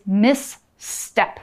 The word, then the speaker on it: misstep: when you see this word misstep, you might be tempted to say, oh, it's miss and then tep. No, it's misstep. Mm -hmm. So you mm-hmm. actually have to say it is two syllables misstep.